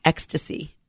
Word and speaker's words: Extase: but it's E-X-T-A-S-E, and it's pronounced ecstasy.